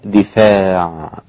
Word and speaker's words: دفاع: الإمام نافع أو الإمام أهل المدينة، والإمام أبو جعفر الإمام المدني الآخر أيضا وهو الإمام الثامن، والإمام يعقوب الإمام التاسع بكسر الدال وفتح الفاء وألف بعدها، يعني كسر الدال يبقى دي وليس دا، وفتح الفاء يبقى فاء وليس دف هكذا. أصبح النطق ولولا دفاع، ولولا دفاع وألف بعدها أي بعد الفاء، دفاع ولولا دفاع ولولا دفع. الباقون طبعا بفتح الدال وسكون الفاء دون ألف كحفص: ولولا دفع الله، ولولا دفاع الله الناس بعضهم طبعا الناس ليس فيها إمالة لأنها ليست مجرورة.